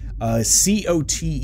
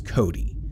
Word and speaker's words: COTE: COTE is the acronym for that, and I have always pronounced that as Cody, not Cote. I've had other people think that it was like that, or Cote.